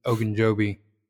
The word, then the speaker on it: Ogunjobi: Ogunjobi.